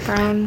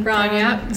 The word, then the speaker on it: Braun: Braun. Who, am I saying that right? Brown? – Brown, yeah.